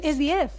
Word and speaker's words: 10: O iPhone X. 10, de hecho. Ah, 10. Es 10. Ah, yo pensaba que era iPhone X.